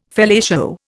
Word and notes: fellatio: Indian

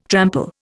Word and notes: trample: Indian

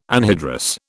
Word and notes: anhydrous: British English

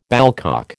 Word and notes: ballcock: American English

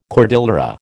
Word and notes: cordillera: American English

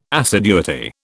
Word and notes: assiduity: British English

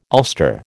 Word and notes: ulster: American English

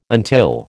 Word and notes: until: American English

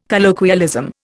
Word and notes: colloquialism: Indian

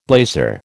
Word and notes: blazer: American English

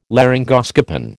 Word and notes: laryngoscopen: American English

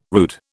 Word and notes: rude: British English

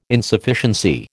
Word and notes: Insufficiency: American English

